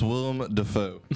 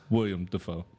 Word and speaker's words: Willem Dafoe: It's pronounced Willem Dafoe. – Yeah. – Willem Dafoe. – Mrs. Fuck. William Dafoe. Willem, sorry.